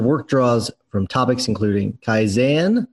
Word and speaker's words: Kaizen: It's Kaizen. – Kaizen. – Okay. Very close. Uh, um, very common though pr- to pronounce it Kaizen.